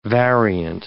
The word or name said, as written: variant